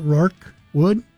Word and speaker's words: Rorque: R-O-R-Q-U-E Wood. Did I pronounce that? Roke Wood? – Sounds like it to me.